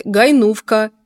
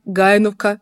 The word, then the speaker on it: Гайновка: До программы я вас спросила, как правильно ставить ударение в названии города Гайновка, потому что мне приходится слышать разные варианты: Гайновка, Гайновка, Хайновка. И вот как все-таки правильно произносить? – Я думаю, что одно и второе правильно, потому что здесь, в Польше, тоже говорят и Хайновка, и Гайновка.